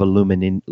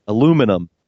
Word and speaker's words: aluminium: Men of aluminium, as the Brits say. Aluminum, as we say.